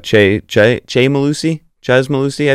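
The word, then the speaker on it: Chez Mellusi: And Braylon Allen and Chez Malusi, I believe is how you say it. Chez.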